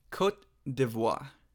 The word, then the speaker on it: Côte d'Ivoire: It's Côte d'Ivoire.